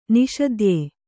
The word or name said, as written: ನಾಸತ್ಯ